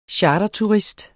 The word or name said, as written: charterturist